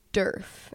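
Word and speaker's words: Djerf: It's D-J-E-R-F. – There's a J? – I think. – Never would have expected. Are you sure it's not dwarf? – No, it's not dwarf. Oh. I think it's Durf is how you say it.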